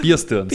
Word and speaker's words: Bear Stearns: Bear Stearns. – Bier wie ein Bier und Stearns wie die Stirn, genau. Bierstirns.